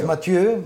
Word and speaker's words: Mathieu: With I feel like I keep saying your name wrong, Mateo. – Yes, Mathieu.